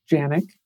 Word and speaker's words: Janiuk: My last name is pronounced Janik. Everyone gets it wrong. We probably pronounce it wrong ourselves because I'm pretty sure it's like a Polish name that would be pronounced very different in Poland.